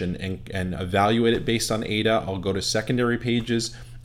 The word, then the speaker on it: AIDA: This is a formula that's existed almost as long as advertising has, and it's called the AIDA formula. You spell that A-I-D-A.